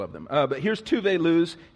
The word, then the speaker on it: Tove Lo: This is uh, Tove Lo's, that's how you pronounce it, research it. She's Swedish. Uh, I know some of y'all been Tove Lows.